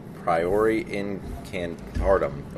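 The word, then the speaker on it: Priori Incantatem: Priori incantum. – Priori incantatum.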